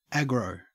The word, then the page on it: aggro: Australian English